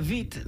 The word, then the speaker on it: Witt: Well, it was Katarina Witt, not Katarina Witt. – Yeah. – Because the W is pronounced like a V.